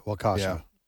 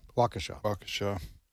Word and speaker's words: Waukesha: Waukesha. – That's just Waukesha.